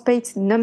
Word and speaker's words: Patz: Donc, Ethan Pate, Alors, ça s'écrit P-A-T-Z. Et apparemment, ça se prononce Pate, Ne me demandez pas pourquoi.